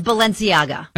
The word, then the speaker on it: Balenciaga: I don't even know how to pronounce this brand. Do you know? – It's Balenciaga.